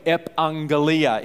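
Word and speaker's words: epangelia (ἐπαγγελία): The Bible word for promise is pronounced this way, epangelia.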